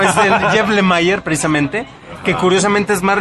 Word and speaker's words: Lemire: Lemire. – Lemire. Esa es la pronunciación correcta.